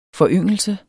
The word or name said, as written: foryngelse